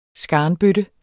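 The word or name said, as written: SKARNBØTTER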